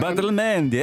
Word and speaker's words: Badalamenti: Badalamenti, yeah.